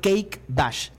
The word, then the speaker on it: Cake Bash: Se llama Cake Bash. Se escribe Kake, C-A-K-E. Bash, como se escucha, con B de burro.